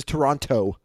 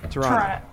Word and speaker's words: Toronto: But do you hear how he pronounces Toronto? He says Toronto. – The T dot. – Instead Toronto. Of Toronto. – Toronto. You know the second T is silent.